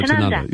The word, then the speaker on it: Tanunda: Yeah, Chateau Tanunda. Yeah, that's it. – Yeah, thank you. Rhymes with Chanda.